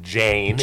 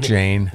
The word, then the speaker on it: Jain: Some that's spelled J-A-I-N, Jane. – Jane.